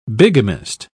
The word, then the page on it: bigamist: British English